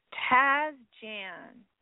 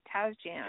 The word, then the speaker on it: Tasjan: is again Aaron Lee, uh, Tajjan's Taz Jan. That's how you say it, Taz Jan.